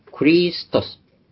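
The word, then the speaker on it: ク リ スト ス (Χριστός): 元の言葉、パウルやヨハネたちが使った言葉では 2000 年前にパンタカイエンパーシンクリストス、ギリシャ語の発音でクリストス、クリストスじゃない、クリストスなんです。後にアクセントがあります。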